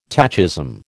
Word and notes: tachism: American English

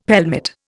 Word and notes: pelmet: Indian

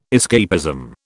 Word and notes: escapism: British English

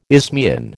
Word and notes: isthmian: American English

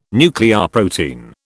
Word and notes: nucleoprotein: British English

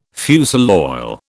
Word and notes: fusel oil: British English

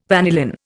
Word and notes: vanillin: Indian

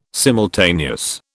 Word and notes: simultaneous: British English

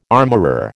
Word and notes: armourer: American English

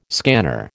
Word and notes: scanner: American English

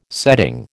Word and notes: setting: American English

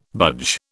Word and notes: budge: British English

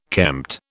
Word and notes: kempt: British English

